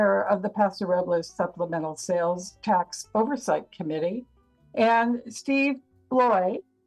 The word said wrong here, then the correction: Blois: Am I saying that right, Steve? – Close enough, it's Blois. – Blois, who is Vice President, NARMAC, and sitting member of the Monterey County Reservoir Operations Committee, or ResOps.